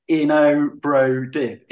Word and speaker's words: Inobrodib: This relates to something else that you said earlier, which is, you know, how do you pronounce the name of your drug? Well, it's Inobrodib.